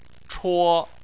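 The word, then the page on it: chuo1: tone 1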